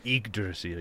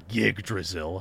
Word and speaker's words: Yggdrasil: Yeah, every set of mythology has its tree Yggdrasil. – Or trees. Yes, Yggdrasil. – Oh, yeah, Yggdrasil. Yeah, that's how it's pronounced. Not Yggdrasil, I was anglicizing it.